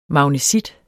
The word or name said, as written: magnesit